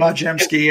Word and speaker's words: Podziemski: Podziemski, yeah.